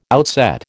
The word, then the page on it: outset: American English